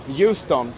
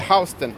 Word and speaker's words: Houston: Och man får inte uttala den Houston, för det är, det är staden i Texas. Då är, då är ni tyska turister om ni gör det. precis, den heter Houston.